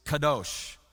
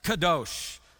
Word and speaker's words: kadosh: It's kadosh. I don't know if I'm saying that. Troy, am I saying it right? I'm getting the thumbs up. Yes, kadosh. Sounds like I'm speaking Klingon, but it's, it's Hebrew. Kadosh. And the Greek word is agios.